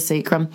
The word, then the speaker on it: sacrum: What's the recovery time on the average contusion of the sacrum? Am I pronouncing that right? – Yeah, it's the sacrum.